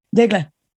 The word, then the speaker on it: Daigle: How do you say it? – Daigle. – Yeah, I would fuck that up. – so I'm like, bagel like bagel.